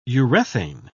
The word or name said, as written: urethane